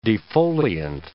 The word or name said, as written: defoliant